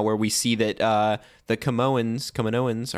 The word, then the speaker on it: Kaminoans: Kaminoans? Am I saying it right? – Kaminoans. Kaminoans. You literally never say it right.